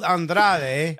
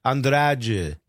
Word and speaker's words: Andrade: But it's spelled Andrade, but the Portuguese language that they speak in Brazil, especially in his accent, you don't really finish the whole thing. And it kind of blends into this Andrade.